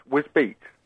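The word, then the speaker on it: Wisbech: That's Wisbeek. – Oh, Wisbeek? – It's pronounced, it's Wisbeek, yes. – Wisbeek? – Yes. – Where the hell is that? – It's not spelt beat, but it's pronounced Wisbeek.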